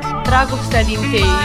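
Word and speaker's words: Dragostea din tei: Hoe spreek je dit uit? – Dragostadin T. It's called Dragostadin T from Ozone.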